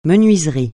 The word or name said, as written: menuiserie